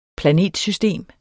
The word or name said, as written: planetsystem